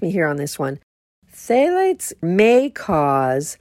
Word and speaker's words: phthalates: is there's no phthalates in it. Now, I know I've said something about phthalates before. Phthalates, it's a big, long word with only two syllables. P-H-T-H-A-L-A-T-E-S.